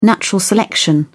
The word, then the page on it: natural selection: British English